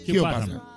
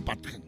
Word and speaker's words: Padgham: El productor Jim Padgham, yo lo digo como se escribe, Padgham, ¿cómo se escribe? ¿Cómo se dice en inglés? – Padgham. Hugh Padgham.